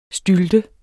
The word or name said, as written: stylte